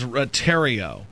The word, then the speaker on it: Thériault: Like Ryan Thériault, like the riot. The riot. – Okay, that makes sense. – That is Thériault.